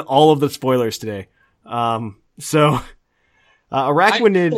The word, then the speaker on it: Araquanid: Which is going to be Araquanid? Is that did I say that correctly? – Araquanid.